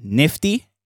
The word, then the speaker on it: NIFTY: I still pronounce the same Nifty, NFT.